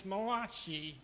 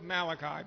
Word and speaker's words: Malachi: Or, as I was corrected, if I was an Italian, I would be pronouncing this Malachi. But I'm going to go with Malachi because that's the way that I was raised to say it.